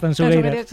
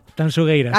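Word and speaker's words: Tanxugueiras: A ver, repítemelo, como tengo que pronunciarlo bien. – Tanchugueiras, Tanchugueiras. – Sí, esto me lo corrige, gusté mucho. Digo, mira que yo siempre pronuncio. A ver, otra vez, dímelo. – Tanchugueiras. – Acaba de deshacerse la manifestación a las puertas de la Gran Vía, de lo bien que lo has dicho.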